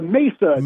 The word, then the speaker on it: Mesa: About just this one little pronunciation, the Mesa. – Mesa, thank you.